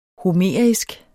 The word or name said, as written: homerisk